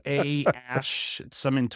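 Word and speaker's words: Æ: The A E is pronounced Ash.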